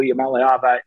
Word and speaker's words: Iamaleava: They have Nico Iamaleava that can really is a great fit for him. – Great on the pronunciation, by the way. I think that's the best I've heard his name pronounced.